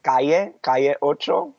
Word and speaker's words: Calle Ocho: It's called Caliocho.